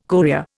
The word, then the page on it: courier: Indian